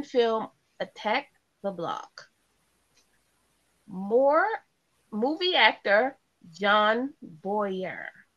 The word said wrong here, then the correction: Boyega: Am I saying that right? Boyedde. – Wait, Boyedde. What? – Boyega. Boyega.